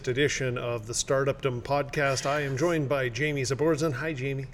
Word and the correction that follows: Zaporzan: That's not how you pronounce my last name. – Zaporzan? – Yeah, that's it.